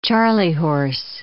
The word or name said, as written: charley horse